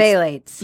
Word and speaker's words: Phthalates: Phthalates.